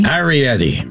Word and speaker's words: Iorietti: Iorietti. Rhymes with spaghetti.